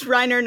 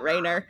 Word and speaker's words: Rainer: It's pronounced Reiner, not Rainer.